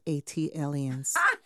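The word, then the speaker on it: ATLiens: That is pronounced Atelians. – Thank you. – Like Atlanta alien, Atelian.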